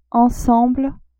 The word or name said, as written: ensemble